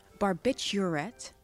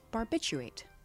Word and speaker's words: barbiturate: Barbiturate, which with the R, is commonly mispronounced as barbiturate. The R is usually left out. – Like the R in library.